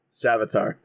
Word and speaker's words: Savitar: Savatier, I think is how you pronounce it. – Savitar? – I think so. – Ah, Savitar, that's it.